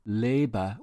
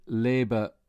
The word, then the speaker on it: lebe: If you want to say, I live in Berlin, you need to pronounce Liebe with an A sound, like Glaswegian lay. I lay on the ground. Lay. If you say Liebe rhyming with Lee, then it could be misunderstood.